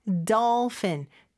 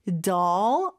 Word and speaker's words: dolphin: 그 다음에 dolphin, 돌핀이 아니죠. doll, 어 발음에 더 가깝고 F 발음으로 fin 해주셔야 돼요.